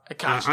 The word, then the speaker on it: Koch: Is that how you say it? Ed Koch.